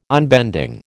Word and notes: unbending: American English

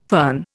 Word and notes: fern: Indian

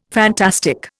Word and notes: fantastic: Indian

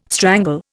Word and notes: strangle: Indian

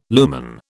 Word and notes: lumen: British English

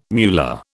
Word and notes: muller: British English

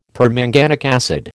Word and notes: permanganic acid: American English

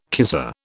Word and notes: kisser: British English

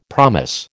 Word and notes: promise: American English

